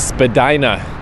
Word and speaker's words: Spadina: This is Spadina, which I thought was Spadina. My buddy Preston Buttons told me I was saying it wrong.